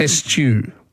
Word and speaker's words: eschew: Can you just say eschew again? – Eschew. – Is that how you say it? – Yes.